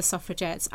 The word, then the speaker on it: suffragette: And initially, the name was actually pronounced suffragette, you know, we're going to get the vote. – Yeah. – So with a hard G rather than the soft G.